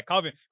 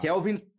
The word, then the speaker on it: Calvin: Mas fala aí a pronúncia correta. Não é Kattar, é Calvin. Kattar. – Não. Kattar. Kelvin Kattar. Kelvin Kattar.